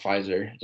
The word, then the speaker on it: Pfizer: But it's spelled Pfizer. But it's pronounced Pfizer, just like the piece.